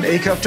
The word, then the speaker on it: Acuff: His name is John Acuff. John, I hope I'm saying that right. Acuff or...